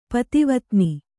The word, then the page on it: ಪತಿವತ್ನಿ: pativatni